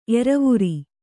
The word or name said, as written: ಏರುವೋರೆ